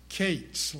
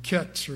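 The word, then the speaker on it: Kates (קץ): The Hebrew word is spelled in English Q-E-T-S. And it's pronounced Kates, like you, K-A-T-E-S. Looks like it'd be Kets or something like that, but it's pronounced Kates, Q-E-T-S.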